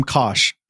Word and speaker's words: Kosh: I'm Kosh, pronounced like Josh, but with a K.